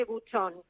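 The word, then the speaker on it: Bucheon: Se escribe Buheon, pero cuando pronuncias en buen coreano se dice Buchon, la ciudad de donde viene.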